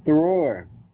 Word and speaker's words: Thrower: Thrower.